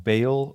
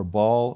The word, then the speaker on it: Baal: You know, here you had the prophets of Baal or Baal or Baal. Okay, all three pronunciations, just pick one and go with it, or choose all three of them if you want.